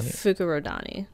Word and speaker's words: Fukurodani: Fukudani? – I think it's Fukurodani.